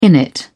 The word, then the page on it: innit: British English